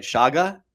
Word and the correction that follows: Chaga: Chaga. – Chaga. Shaga sounds like a dance.